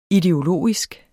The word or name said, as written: ideologisk